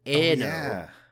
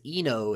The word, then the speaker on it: Anno: There's also a room full of the Anno guys, or Anno, which thank you, Lokothor, for DMing me the correct pronunciation, because if it was Eno, it would be I-N-O in Japanese.